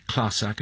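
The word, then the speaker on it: Clàrsach: Its proper name is the Clasach, and I make sure I pronounce that, I think, correctly, yes. – But tell me if I'm not.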